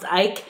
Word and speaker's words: Icke: It was the same thing with David Icke. Oh, I wanted to apologize. I think his name is pronounced Icke. – Yes. – I said it Ick the whole time.